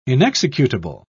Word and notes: inexecutable: British English

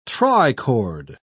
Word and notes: trichord: British English

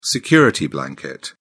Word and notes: security blanket: British English

